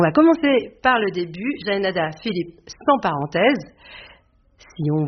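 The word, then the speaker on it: Jaenada: Mais quand on me demande la manière de le prononcer, moi je dis Jaénada,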